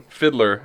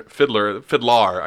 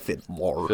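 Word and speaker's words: FIDLAR: Right now, I've been digging Fiddler, and they're Fiddlar. I guess you want to say it like that. – Yeah, it's like Fiddlar.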